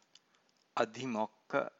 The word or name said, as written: adhimokka